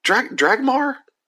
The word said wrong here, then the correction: Drachma: Drachma? Drachma.